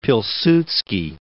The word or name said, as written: Pilsudski